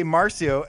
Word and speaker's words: Marcio: I'll say Markio, and then you'll say Marcio, and then I called him Marcio for a week, and he's like, dude, it's Markio.